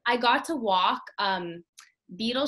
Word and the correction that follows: Beale: Beale Street.